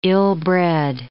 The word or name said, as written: ill-bred